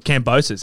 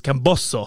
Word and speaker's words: Kambosos: Apparently, it's not Kambosos. Everyone in the media is Kambosos. I said, "Hey, no, Daniel, it's Camboso." – Oh, really? – It's a pronounce Camboso.